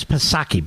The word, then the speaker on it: Psaki: And they do have the whitest person who's ever served a president there, Jen Psaki. What kind of name is Psaki, by the way? The P is silent, of course.